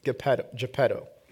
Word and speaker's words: Geppetto: Geppetto.